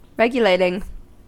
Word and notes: regulating: American English

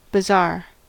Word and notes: bazaar: American English

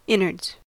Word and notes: innards: American English

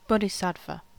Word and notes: bodhisattva: American English

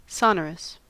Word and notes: sonorous: American English

